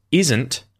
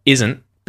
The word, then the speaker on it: isn't: The only little difference in pronunciation is going to be that because isn't, right, like is not contracted together, isn't, because it ends with a T, you're going to mute that T and the sound stops at the end of the word isn't.